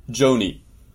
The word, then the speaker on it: Joni: No, do you pronounce it Joni or Johnny? – Joni. – Joni, yeah, like Joni Mitchell? Okay, yeah. I just want to make sure I got this right. I don't want to mispronounce. It looks like Joni.